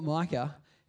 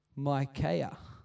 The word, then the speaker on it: Micaiah: Do you know, like the guys like Elijah and Elisha, and there's this guy whose name is not Micah, it's Micaiah, apparently, which is a different dude from Micah.